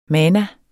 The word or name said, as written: mana